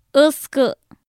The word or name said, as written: ɨskɨ